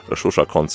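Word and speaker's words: Zsuzsa Koncz: Shusha Konz, die Schreibweise, die erfordert Konzentration, will ich ihn gar nicht genau buchstabieren, aber Shusha Konz, so kann man es aussprechen.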